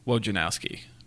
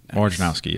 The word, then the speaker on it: Wojnarowski: I mean, you see like Adrian Wojnarowski or whatever. That's how you say his name, mm-hmm. right? Okay, Wojnarowski. – Wojnarowski, yeah. I think Wojnarowski.